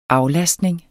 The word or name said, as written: aflastning